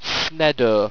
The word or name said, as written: sneddo